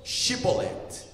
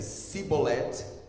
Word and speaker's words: Shibolet: Se respondessem que não, próxima página, diziam: então digam Shibolet. Se ele dissesse Sibolet, sem conseguir pronunciar corretamente a palavra, prendiam-no e matavam-no no lugar da passagem do Jordão.